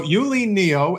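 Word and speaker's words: Yuh-Line Niou: Phonetically, Yulin Neo is her name. – Oh, it's a very nice name. So Yulin Neo, as many of you may know, is running for U.S.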